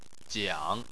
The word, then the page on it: jiang3: chinese